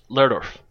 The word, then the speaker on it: Lerdorf: Lerdorf.